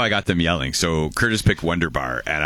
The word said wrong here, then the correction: Wunderbar: I'm like, "You're not saying it right. It's Wunderbar!" And they both immediately reflexively yelled Wunderbar!